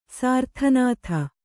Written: ಸಾರ್ಥನಾಥ

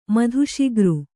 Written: ಮಧುಶಿಗ್ರು

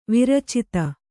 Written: ವೀರೋಚಿತ

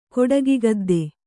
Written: ಕೊಡಗಿಗದ್ದೆ

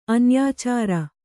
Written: ಅನ್ಯಾಚಾರ

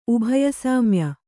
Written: ಉಭಯಸಾಮ್ಯ

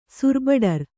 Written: ಸುರ್ಬಡರ್

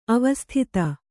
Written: ಅವಸ್ಥಿತ

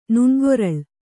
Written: ನುಣ್ಗೊರಳ್

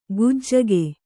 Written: ಗುಜ್ಜಗೆ

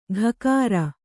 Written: ಘಕಾರ

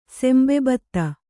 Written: ಸೆಂಬೆಬತ್ತ

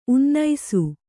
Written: ಉನ್ನಿಸು

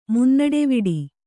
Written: ಮುನ್ನಡೆವಿಡಿ

